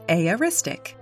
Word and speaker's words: aoristic: It's aoristic. That's spelled A-O-R-I-S-T-I-C.